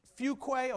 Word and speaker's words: Fuquay: Fuqua, is that how that's it? Fuquay, okay, it looks like Fuquay.